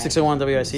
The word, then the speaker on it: 601 WIC: Am I saying that right? – 601 WIC, yeah.